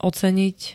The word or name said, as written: oceniť